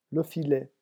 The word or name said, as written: le filet